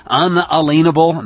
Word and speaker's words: unalienable: unalienable, that's the correct pronunciation, by the way, rights, the kind of rights that don't come from any government on earth, so they can't be taken away.